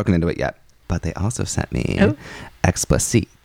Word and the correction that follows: Explicite: I think it has also the Beyonce dash on the E. – God damn it. – Yes. Explicite is such a power scent.